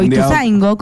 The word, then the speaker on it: Ituzaingó: O Ituzaingó, ¿cómo se dice? – Ituzaingó. – Pues tiene acento en la O.